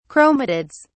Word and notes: chromatids: British English

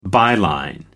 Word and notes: byline: American English

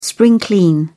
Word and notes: spring clean: British English

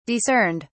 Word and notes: discerned: British English

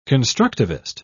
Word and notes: constructivist: British English

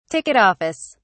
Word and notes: ticket office: British English

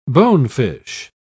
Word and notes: bonefish: British English

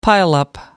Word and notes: pileup: British English

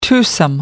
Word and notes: twosome: American English

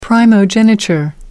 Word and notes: primogeniture: American English

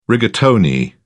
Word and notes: rigatoni: British English

